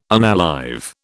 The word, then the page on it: unalive: British English